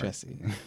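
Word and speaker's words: Jussie: There's a whole Jesse. I'm saying it right. – Yes, Jesse. You are saying it right. – Jesse.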